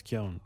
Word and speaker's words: Keoghan: We said it to, when we interviewed Barry, he said his name is Keoghan, but like he doesn't mind, he doesn't care.